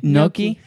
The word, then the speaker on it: gnocchi: Crab cakes, tuna tartare, gnocchi. – Gnocchi. – Gnocchi. – Gnocchi. – There you go. You got it.